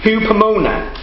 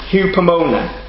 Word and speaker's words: hupomone: It's pronounced hupomone, hupomone.